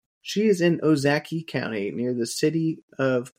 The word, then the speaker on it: Ozaukee: It's O, like, oh boy, za ki Ozaukee. Ozaukee. the intro there is a Ozaukee. – Jeez. Ozaukee. It's like when you see a cute key.